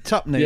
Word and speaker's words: tuppenny: It's pronounced tuppenny, isn't it? – Okay. Yeah. Oh. – Tuppenny.